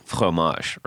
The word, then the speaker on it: fromage: Now we say fromage, but you're supposed to go like fromage, right? – And you don't even pronounce the GE at the end.